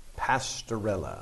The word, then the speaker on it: Pastorella: No, it's like Cinderella. – Pastorella.